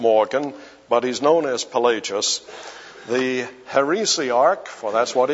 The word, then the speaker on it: Pelagius: Now, Pelagius is spelled P-E-L-A-G-I-U-S, Pelagius.